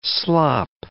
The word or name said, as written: slop